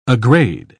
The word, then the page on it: aggrade: British English